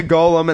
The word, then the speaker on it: golem: He's going to make a golem, and the golem is going to go out and house that motherfucker, okay? – A golem or a golem? – Golem. That's how you say it.